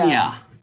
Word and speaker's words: Anja: Anya.